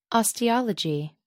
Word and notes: osteology: American English

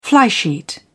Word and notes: flysheet: British English

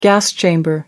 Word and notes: gas chamber: American English

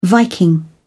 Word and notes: viking: British English

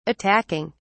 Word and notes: attacking: British English

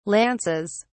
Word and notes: lances: British English

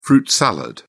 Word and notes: fruit-salad: British English

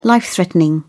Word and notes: life threatening: British English

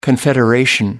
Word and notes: confederation: American English

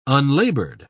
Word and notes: unlaboured: British English

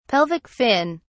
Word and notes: pelvic fin: British English

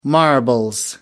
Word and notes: marbles: British English

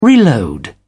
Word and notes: reload: British English

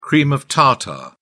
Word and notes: cream of tartar: British English

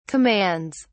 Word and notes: commands: British English

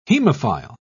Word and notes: hemophile: British English

